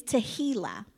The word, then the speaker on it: tehillah: So singing, the Hebrew word for singing is tehillah, and that is spelled T-E-H-I-L-L-A-H, tehillah. – Tehillah, not tequila.